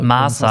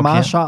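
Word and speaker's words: Mahsa: Marsha Amini. Ganz viele sagen Marsha, aber es ist ja HS und nicht SH.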